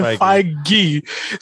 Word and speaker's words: Feige: But Kevin Feige said that, you know, he jokingly said, yeah, with the hard G, he jokingly said that the trailer for Spider-Man was going to come out before the movie, right?